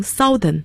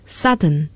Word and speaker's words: Southern: N-O-R-T-H-E-R-N 那么南方的英文是 S-O-U-T-H-E-R-N 要注意发音，很多人有可能读成 Southern, 但是正确的发音是 Southern, Southern, OU 字母组合读成了啊这样的声音。